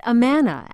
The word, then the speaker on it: Amana: The town is actually pronounced Amana, after the Amana Church Society.